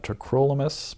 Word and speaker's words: tacrolimus: By the way, some people say tacrolimus. I have looked up the pronunciation and it's supposed to be pronounced tacrolimus, but I'll accept tacrolimus if I'm working with you on the wards.